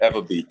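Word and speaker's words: Ewa: Is it Iwa Beach or is it Iwa Beach? I never know how it's pronounced. Yeah, but Eva Beach.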